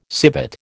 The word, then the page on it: civet: American English